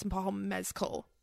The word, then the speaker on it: Paul Mescal: Cause I feel like, and I so badly want to say Paul Mescal, but it's, or Paul Mez. Yeah. Paul Mescal, but it's Paul Mescal. Cause she found out from Andrew Scott, that is how you say his name.